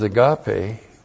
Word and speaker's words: agape: Now, everything else that comes after that first word is agape. First word is agape. A-G-A-P, long E.